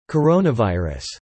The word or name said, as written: coronavirus